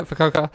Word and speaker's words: Fukuoka: Okay, so you go to Japan, you play for Fukuoka, is that you pronounce it? – Yeah, Fukuoka.